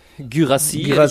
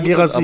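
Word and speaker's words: Guirassy: der Typ, der, der Herr G. Gurashi heißt er? Girassi. ja, Girassi. ja, ja, ja, stimmt.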